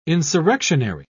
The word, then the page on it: insurrectionary: British English